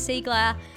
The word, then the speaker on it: Cygler: It's Siegler, which is spelled C-Y-G-L-E-R.